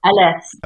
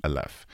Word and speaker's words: Aleph: Aleph. – Aleph. So A-L-E-P-H.